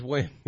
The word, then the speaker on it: Nguyen: It's like 10-10 T Wen. – Oh. – She's on Nguyen. – Yep. – She's Win. Yeah, New Win.